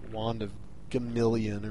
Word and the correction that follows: Gamelon: or whatever, however you pronounce that piece Gamelon.